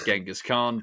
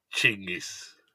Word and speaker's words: Chinggis: And another thing is that we've all been pronouncing his name wrong. Everyone says Genghis Khan, but actually... – It's Chinggis. – Chinggis Khan, yes, is the more correct pronunciation.